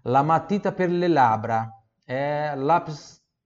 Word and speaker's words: matita: lamatita, Aqui também, ó. Cuidado. Cuidado com a pronúncia do ti. Novamente, igual fundo tinta, lamatita, lamatita, Pronúncia pura do T ali, tá bom?